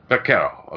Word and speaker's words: Ivana Baquero: Is that how you pronounce that, Ivana? – Baquero.